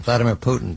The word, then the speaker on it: Vladimir Putin: There's only one person, Vladimir Putin.